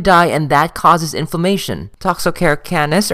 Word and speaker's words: Toxocara canis: Our next trematode transmitted by eggs is Toxocara canis. The British would pronounce this as Toxocara canis, as well as Toxocara cati.